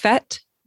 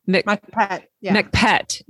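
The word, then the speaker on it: Mak Phet: So when I make this to photograph it, I think that I have a handle on the, you said Jiao Mak Pet. – Mak Pet. – Not, it's not PH for us. It's pet. – The H is silent.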